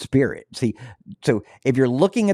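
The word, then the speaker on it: spiorad: But the actual correct Gaelic pronunciation, even though the word doesn't look like it's pronounced Spirit, the actual Gaelic pronunciation is in fact Spirit.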